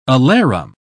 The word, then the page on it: alarum: British English